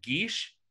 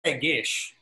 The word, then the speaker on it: Gish: I say Gish. – Oh, I thought you called it Geesh earlier, and I was like, have I been saying it wrong ever? – I might have had might have had some ice in my mouth, you know, but I think it's pronounced Gish.